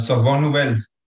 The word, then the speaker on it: Sorbonne Nouvelle: ¿Cómo se pronuncia en francés? Sorbonne, no sé qué vaina. – Ah, Sorbonne Nouvelle. Exacto, Sorbonne Vainel.